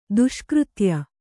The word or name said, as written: ದುಷ್ಕೃತ್ಯ